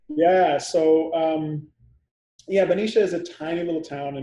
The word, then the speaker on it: Benicia: Yeah, Benicia.